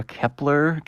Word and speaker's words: Kep1er: Uh, I, I, I assume their name is meant to be pronounced uh, Kepler, Kepler, because it, cause it looks like the phrase Kepler, but there's a one instead of the L, you know, because we gotta get fancy with our linguistics now these days in order to really stand out with band names.